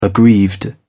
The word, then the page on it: aggrieved: British English